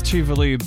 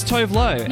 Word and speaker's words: Tuvalu: Apparently it is pronounced Tuvalu, but for years people would just see it and go, oh, it's Tovlo. – Yeah, Tovlo. That's a very Australian way to say it.